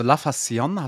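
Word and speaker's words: La Facción: Kriegt ein Safe hier drin und äh, ja, die, die, wie, kannst du nochmal die La Fassion, wie, ist das richtig das heißt, ausgesprochen? Fast, also La Fassion, hast du richtig gesagt?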